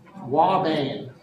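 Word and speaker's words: ouabain: Pretend like that's a W. Wabane. Doesn't look like it would be a W. Looks like an OU.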